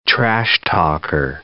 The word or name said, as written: trash-talker